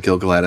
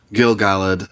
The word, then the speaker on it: Gil-galad: So the whole part where Gil-galad gives his speech, and I pronounce it Gil-galad as you do, Gil-galad, some people like.